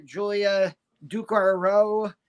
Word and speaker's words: Julia Ducournau: Um, it's by uh, French filmmaker Julia Ducournau, I believe is how you say her name.